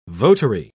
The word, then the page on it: votary: British English